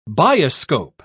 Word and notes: bioscope: British English

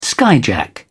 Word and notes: skyjack: British English

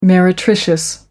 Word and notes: meretricious: American English

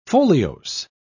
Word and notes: foliose: British English

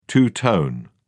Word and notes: two tone: British English

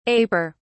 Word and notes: aper: British English

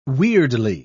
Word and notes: weirdly: British English

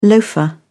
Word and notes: loafer: British English

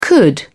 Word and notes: could: British English

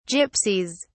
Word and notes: gypsies: British English

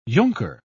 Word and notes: junker: British English

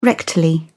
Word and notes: rectal: British English

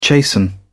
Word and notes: chasten: British English

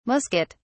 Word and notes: musquet: British English

